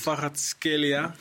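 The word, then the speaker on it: Kvaratskhelia: Farhad Skelia. zeg ik het goed, hè? – Zeker.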